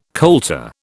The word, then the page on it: colter: British English